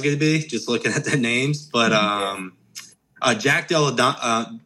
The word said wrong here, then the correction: Della Maddalena: Della Maddalena.